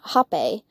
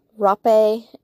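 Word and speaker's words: rapé: has been hape. This is also called rape, and it's spelled H-A-P-E or R-A-P-E.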